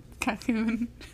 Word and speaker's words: Colquhoun: I was going to say Colquhoun. Why is her Q? – Calhoun. – Calquoon. Okay. Sorry.